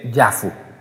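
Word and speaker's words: Yafo: se escribe como Jafa, pero sin embargo se dice Yafu, se escribe Jafa, J-A-F-A, Jafa, pero se dice Yafu.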